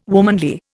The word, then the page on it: womanly: Indian